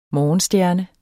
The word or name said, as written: morgenstjerner